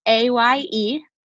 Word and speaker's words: Ayelet: Yes, it's Ayelet.